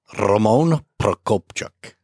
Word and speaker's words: Roman Prokopchuk: You want me to do it? Roman Prokopchuk. – That's good. That's really, really good.